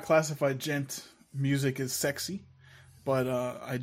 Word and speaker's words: djent: D-J-E-N-T. – Yes. – And it's pronounced what? – Gent. The D is silent.